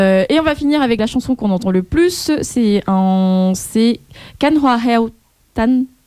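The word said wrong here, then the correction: Cánh Hoa Héo Tàn: Bref, I mispronounced the last word. – Kang Hoa Heo Tan. – Yes, thank you, Jenny.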